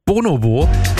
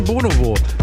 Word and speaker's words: Bonobo: Und zwar, jetzt gibt's was Neues von Bonobo. Die meisten sagen Bonobo. Dabei heißt das Bonobo. Weil es ist ja ein britischer Künstler, der Bonobo. Und da wird es so ausgesprochen. Nicht nur der Künstler wird so ausgesprochen,